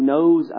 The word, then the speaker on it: knows: Jesus is also the one who knows us. That Jesus names us, and that Jesus also knows us. I'm trying to go with the alliteration, but there is a silent K, I recognize that.